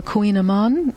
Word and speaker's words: kouign-amann: But then there's a lot of totally new things, like on the cover, the pastry that's called the kouign-amann. Mm-hmm. I think of it as the Queen of Pastry because if you look at the spelling, K O U I G N, you'd never pronounce it that way.